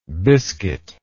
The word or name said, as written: biscuitlike